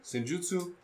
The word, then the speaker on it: Senjutsu: Senjutsu.